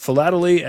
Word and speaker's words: philately: Dan Piazza is chief curator of philately at the Smithsonian National Postal Museum. Dan, in my business, it's dangerous to mispronounce words. Did I say that right? Philately? – You did. You got it right.